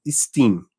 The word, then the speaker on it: STEAM: E você organizou o primeiro congresso internacional de mulheres em STEAM, o Simesteam, onde Steam, eu estou usando a pronúncia em inglês,